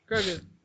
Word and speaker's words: Grogu: Mandalorian fabric with like illustrations of Mandalorian and Grogu. I said his name right? – Yep. – Okay. Because sometimes I like don't. – No, it's Grogu.